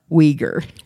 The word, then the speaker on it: Uyghur: And to enforce his law, Temujin ordered the creation of a writing system based on the Uyghur alphabet, and that is spelled U-Y-G-H-U-R, Uyghur.